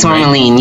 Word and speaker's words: Tourmaline: Tourmaline, is that how you pronounce her name? – I think it's Tourmaline, right? Yes.